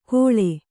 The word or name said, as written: ಗೋಳೆ